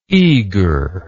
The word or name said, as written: eagerly